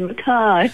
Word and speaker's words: Mackay: Oh, definitely Mackay. I'm giving one little anecdote. The grandfather, in his crustier old age, used to refuse to acknowledge people who called him Mackay. He felt so strong, called him Mackay. Mackay is proper Scottish pronunciation.